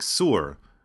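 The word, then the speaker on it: Sur (صور): Chapter 23 returns to the national stage with a pronouncement about Tsor, or Tyre, or as it's pronounced in Arabic, Sur.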